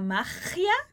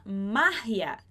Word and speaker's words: magia: E uma coisa bem interessante com relação à palavra magia, em português a gente fala magia, em espanhol a gente fala magia. Então, a sílaba mais forte vai mudar entre o português e o espanhol.